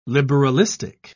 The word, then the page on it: liberalistic: British English